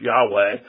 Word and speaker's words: Yahweh: When you say Yahweh or Jehovah, most think it's better pronounced Yahweh, that simply is the verb to be, and it's in the third person.